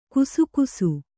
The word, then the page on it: ಕಸಕಸೆ: kasakase